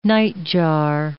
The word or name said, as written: nightjar